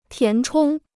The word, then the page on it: 填充: tián chōng